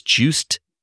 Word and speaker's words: Jaeckin: Now, this movie, which came out in the 1970s, and directed by a filmmaker whose name is pronounced very differently, but looks like Just Jackin, when you write it down, is Juiced, J-A-E-C-K-I-N.